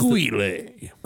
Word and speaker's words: Guile: Now this is Guile. – I don't. Maybe that's how it's pronounced.